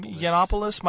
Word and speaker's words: Yiannopoulos: How do you say his last name? Yiannopoulos. Milo Yiannopoulos, formerly of Breitbart.